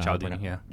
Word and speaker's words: Cialdini: Cialdini.